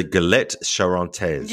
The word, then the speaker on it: Galette Charentaise: Let's say this properly. The Galette Charentaise. – Yes, thank you. Right? – Yes, that's right. Yay. Galette Charentaise.